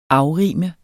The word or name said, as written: afrime